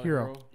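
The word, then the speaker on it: Herro: How do you pronounce that? Tyler Hero. – Hero. – Hero. Okay. There's two R's, though, right? – Yeah.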